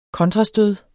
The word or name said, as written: kontrastød